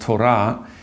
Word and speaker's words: Torah: Well, it is a Hebrew word, actually it's pronounced Torah, that is translated in most Christian Bibles as law.